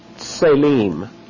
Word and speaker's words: tselem: The first word is pronounced tselem. Looks like this. We'll T-S-E-L-E-M. Tselem, translated image.